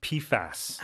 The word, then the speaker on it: PFAS: Yeah, I should correct myself. I think it's pronounced PFAS.